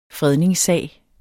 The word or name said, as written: fredningssag